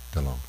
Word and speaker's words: Delon: I think it was Alain Delaine. How do you pronounce his name? Delon.